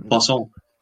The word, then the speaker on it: poisson: S, but it's got one extra S, but it's spelled the same way as poison. – Yeah, because it's French for fish, poisson. – And it says it's basically poison, but with a French accent.